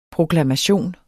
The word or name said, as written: proklamation